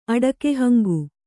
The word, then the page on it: ಅಡಕೆಹಂಗು: Kannada